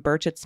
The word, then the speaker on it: Burchett: I'm in Tennessee, and our rep, Tim Burchett's name is pronounced Burchett. Oh, cool. I said it right. Uh, I'm sorry if I said it wrong before, Kimberly. We like to tell people it sort of rhymes with bird shit.